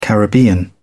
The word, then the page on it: caribbean: British English